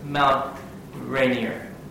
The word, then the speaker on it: Mount Rainier: Off in the distance was Mount Rainier.